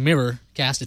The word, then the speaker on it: Miracast: I'm not mispronouncing Miracast. It's Miracast, M-I-R-A, cast.